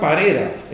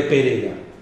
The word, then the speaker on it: Parera: Se ve que él lo pronunciaba, como se pronuncia correctamente en catalán, pronunciaba Parera en lugar de Pereira.